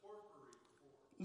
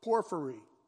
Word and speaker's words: Porphyry: His name is Porphyrophor. That should be an H. I don't know how you pronounce it. – I think you pronounced it Porphyry. There you go. Porphyry.